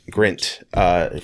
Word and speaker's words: Grint: And then, of course, you had Rupert Grant. Or is that, say that right? – Rupert Grant. – Grint. From Harry Potter.